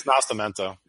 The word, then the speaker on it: Nascimento: It's Nascimento.